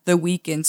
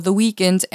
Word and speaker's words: The Weeknd: Men der var jo et kanadisk band, der kaldte sig The Weeknd, så det kunne han jo ikke også hedde. Så han fjernede derfor edet i The Weekends, selvom man udtaler det på samme måde.